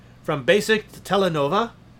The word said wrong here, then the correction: telenovela: Telenovela. Sorry, I pronounced that wrong because I don't really speak Spanish, but that was the whole episode of that one.